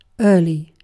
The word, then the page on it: early: British English